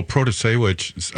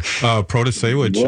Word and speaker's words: Protasiewicz: Well, Protasiewicz, I got to say it right there. Protasiewicz. – Well done.